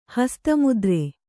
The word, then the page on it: ಹಸ್ತಮುದ್ರೆ: Kannada